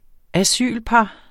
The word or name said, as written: asylpar